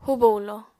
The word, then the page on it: hubo-hulo: apatani